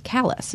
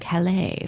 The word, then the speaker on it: Calais: He wants to know how Callis became pronounced Callis instead of Calais for the French.